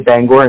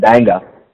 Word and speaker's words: Bangor: So you can always tell somebody's been to Maine because they'll say Bangor instead of Banga.